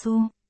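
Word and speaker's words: Su (苏): Anh họ Tô, à, Tô trong tiếng Trung phát âm là Su.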